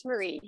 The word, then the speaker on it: Marie: And I hope it's not Mary, if I've been butchering that from the beginning. – No. Good morning. It's Marie.